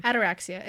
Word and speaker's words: Ataraxia: Ataraxia. It sounds like arachnia, like a spider, which is why I remember it, because I fucking hate spiders.